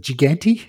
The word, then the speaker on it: Gigante: But, yeah, obviously the car that Rimspoke himself makes, which does have a name, they call it El Tempo, is it Gigante, how they pronounce it? – Yep, that's good enough for me.